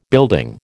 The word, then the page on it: building: American English